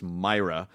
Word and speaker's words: Mira: But Matt, one of Matt's biggest crosses to bear is that his last name is pronounced Myra. – Yeah. – But of course, in Los Angeles, Mira. – Where there's a large Latino population, it's Matt Mira.